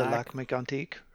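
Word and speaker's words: Lac-Mégantic: It doesn't really feel like that, but yeah, we're talking about the, let's pronounce this correctly because it's French. The Want to help? – the Lac Mégantic, right?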